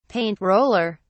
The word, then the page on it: paint roller: British English